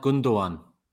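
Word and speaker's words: Gündoğan: Do you pronounce his name? Is it pronounced Gundogan rather than Gundogan? Was I pronouncing it correct? – It's pronounced Gundogan.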